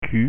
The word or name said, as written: q